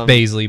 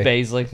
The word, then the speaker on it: Bazley: It's Bazley, by the way. – Bazley.